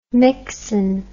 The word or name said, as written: mixen